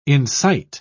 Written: incite

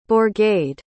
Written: bourgade

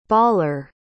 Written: bawler

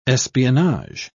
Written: espionage

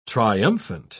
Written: triumphant

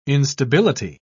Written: instability

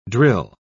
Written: drill

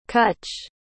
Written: kutch

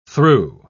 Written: thru

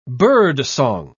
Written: birdsong

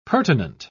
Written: pertinent

